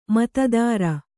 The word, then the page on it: ಮೋತಾದಾರ: mōtādāra; kannada